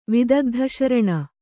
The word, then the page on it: ವಿದಗ್ಧಶರಣ: Kannada